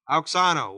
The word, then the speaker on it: Auxano: Auxano. A-U-X-A-N long O. Auxano. What does it mean?